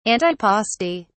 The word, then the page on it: antipasti: British English